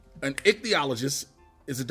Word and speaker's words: ichthyologist: ICHTYOLOGIST. – THY. – THY, excuse me. Ichthyologist. – Say that one some more. – Ichthyologist.